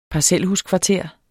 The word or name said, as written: parcelhuskvarter